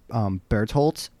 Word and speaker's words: Bertholdt: Yeah, I never really know how to pronounce his name, but I think it's Bertholdt. Like, That sounds like it, right. – Yeah, because the TH, most of these names are, I believe, of German origin, so they don't have a TH sound.